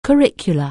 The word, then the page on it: curriculum: anglais